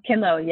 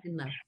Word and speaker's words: KINLO: Am I pronouncing it right? KINLO? – KINLO, yes. Golden, yes. KINLO.